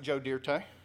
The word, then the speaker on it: Dirt: To quote the great American philosopher, philosopher Joe Dirt, some pronounce it Joe Dirte.